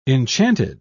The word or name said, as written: enchanted